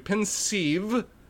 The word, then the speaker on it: Pensieve: The Pensieve look, I pronounced it once and the only right time I pronounced it the entire damn time.